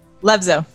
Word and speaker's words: Levzow: LEVZOW.